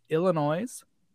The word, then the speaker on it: Illinois: They actually fell to Illinois. It's noise. They spell it with the N-O-I-S. – Illinois and it's not plural. It's only one.